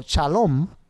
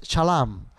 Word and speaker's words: Shalom: So Shalom se escribe eh, S H L M. And so depending on the tradition of the tribe, one tribe says Shalom. and the other one says shalom, and the other one says shalem.